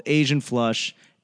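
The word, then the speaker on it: AZN Flush: They sent me a free game and it's called Asian Flush. And, you know, it's spelled A-Z-N flush.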